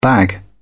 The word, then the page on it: bag: British English